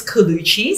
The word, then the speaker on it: Collucci: Amanda, A-M-A-N-D-A, and then my last name is Colucci, C-O-L-L-U-C-C-I.